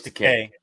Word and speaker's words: Takei: Have I been saying his name wrong my whole life? Is it not Takei? – It's George Takei. – Is it? All right. – Yeah. As he would say, Takei rhymes with gay.